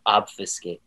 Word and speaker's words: obfuscate: Whoa, obfuscate. – Love the word obfuscate, Connor. – That was, and it like rhymes with facilitate.